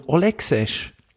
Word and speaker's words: Olexesh: Ich nehme passenderweise, so als Kontrastprogramm, nehme ich mal vom Olexesh. – Wie schreibt man das? – O-L-E-X-E-S-H.